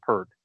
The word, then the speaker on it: Peart: I think it's Peart is how it's pronounced, but everybody calls it Pert, and it looks like Pert.